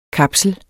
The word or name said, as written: kapsel